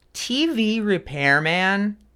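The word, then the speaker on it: TeeVee Repairman: Yo, well, TV Repairman is maybe the best band name that I've seen in quite some time.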